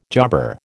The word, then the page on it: jobber: American English